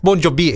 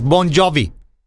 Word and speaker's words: Bon Jovi: Entonces esta persona me decía, no es Bon Jovi, es Bon Jovi.